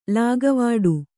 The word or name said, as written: ಲೆಕ್ಕವಿಡು